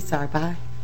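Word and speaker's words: Zahrbock: My name is Carrie Zarbach. Carrie is C-A-R-Y. Zarbach is Z as in zebra, A-H-R-B-O-C-K.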